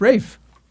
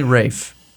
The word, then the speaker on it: Ralph: It's Rafe. That's how you pronounce it. Check it. – I don't know if we're talking about the same person. – Ralph Fiennes. It's not Ralph Fiennes. It's Ralph Fiennes. That's how you say it. It's definitely Rafe.